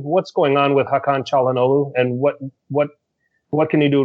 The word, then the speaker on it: Çalhanoğlu: Steve, can you please correct him on the pronunciation of the name? – Çalhanoğlu. – Thank you. Go ahead. – Çalhanoğlu? – I have no idea. – It's the C pronunciation.